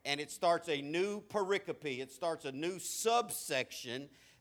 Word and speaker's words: pericope: That's a funny word because it's spelled pericope, but it's pronounced pericope.